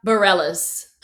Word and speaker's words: Bareilles: Sarah Bareilles.